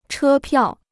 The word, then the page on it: 车票: chē piào